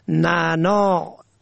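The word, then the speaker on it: نعناع: در زبان فارسی از اثر کثرت کاربرد عین آن از تلفظ افتاده است، اما در فارسی شیوا باید آن را نعنا نوشت، یعنی نون عین الف عین، و تلفظ کرد.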